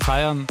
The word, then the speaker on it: Fayan: Wenn jemand eine schwere Zeit hinter sich hat, also ihr vielleicht, dann war das genau der richtig ideale Song, sich selber zu feiern. Verliebt in mich. Im Feelgood-Hitmix im Bayern 3 von Feiern. Nein, den schreibt man nicht wie Feiern, sondern F-A-Y-A-N. Also F-A-Y-A-N, wenn ihr ihn suchen solltet, ne?